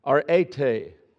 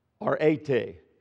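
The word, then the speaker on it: arete (ἀρετή): And this word virtue and translation of the Greek is pronounced, and I'll get it here in just a second, arete, A-R-E-T-A-Y, arete.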